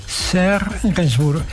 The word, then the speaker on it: Serge Gainsbourg: Escrito por Serge Gainsbourg. ¿Lo pronuncio bien? Serge Gainsbourg, perfecto.